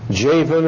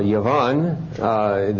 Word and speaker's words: Javan: The sons of Japheth were Gomer, Magog, Madai, Javan, or Yavan, the Hebrew would pronounce a J as a Y, Tubal, Meshech, and Tiras.